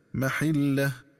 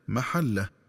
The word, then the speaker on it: محلة: وكذلك ينبغي التنبه للنطق الصحيح للفظ محلة، فيقرأ بكسر الحاء هكذا محلة، ومن الخطأ قراءته بفتح الحاء هكذا محله.